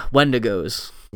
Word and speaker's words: Wendigos: Wendangos. – Wendigos. – Wendigos. Wendangos. Wendigos. – I think they pronounce it Wendigos in the No, you're correct. I said it wrong.